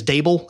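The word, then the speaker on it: Daboll: I am going to be interested to see how the Brian, I think his last name is Dable, that's how you pronounce it, offensive coordinator from Buffalo, who's now the head coach at New York.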